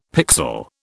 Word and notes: pixel: British English

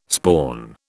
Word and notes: spawn: British English

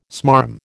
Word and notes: smarm: American English

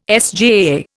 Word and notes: SJAA: Indian

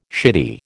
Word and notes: shitty: American English

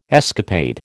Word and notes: escapade: American English

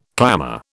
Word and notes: clamour: British English

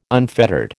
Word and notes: unfettered: American English

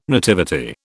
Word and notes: nativity: British English

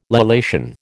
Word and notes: lallation: American English

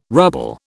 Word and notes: ruble: British English

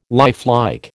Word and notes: lifelike: American English

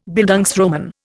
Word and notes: Bildungsroman: Indian

